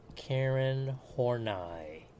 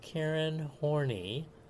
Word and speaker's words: Karen Horney: All right, now another Neo-Freudian is, and please pronounce her name correctly, Karen Horney. It's German, okay? Not Karen Horney. You get in a lot of trouble.